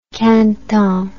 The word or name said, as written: caneton